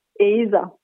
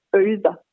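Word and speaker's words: eyða: For eksempel, hvis man tager ordet at lægge noget øde, æder, vil man så sige på moderne islandsk. Men i det gamle sprog, der bliver den her diftong eller tvillyd, e-y, den bliver udtalt øje, så man siger øder i det gamle sprog.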